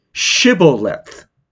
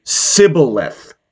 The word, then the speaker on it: Shibboleth: And when he said, no, he would say to him, then say, Shibboleth. And he would say, Sibboleth, for he could not pronounce it right.